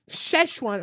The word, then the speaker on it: Szechuan: Today, I went to the grocery store and I bought myself some Szechuan.